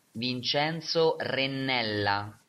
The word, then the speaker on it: Vincenzo Rinella: Vincenzo Rinella. – So, you all have homework. Make sure you get that, roll your R's.